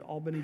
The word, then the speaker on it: Albany: And that's how you say it if you're from South Georgia, Albany, Georgia.